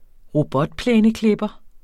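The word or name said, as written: robotplæneklippere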